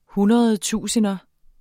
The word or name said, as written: hundredtusinder